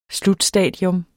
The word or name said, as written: SLUTSTADIET